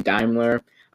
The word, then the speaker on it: Daimler: Also, to talk about that, when I was saying Daimler, it was actually Daimler. I only realized that after I watched. I'm like, oh, wait, I said it wrong.